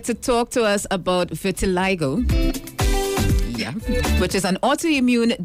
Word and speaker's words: vitiligo: Can you pronounce the word for me? Because I hear so vitiligo. – Because I hear so many different pronunciations, you know.